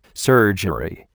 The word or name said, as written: surgery